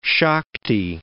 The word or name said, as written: Shakti